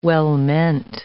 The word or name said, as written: well-meant